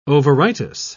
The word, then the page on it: ovaritis: British English